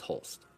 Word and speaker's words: Hulse: He's like, this is how you pronounce it. It's Pulse, but with an H. So it's Hulse.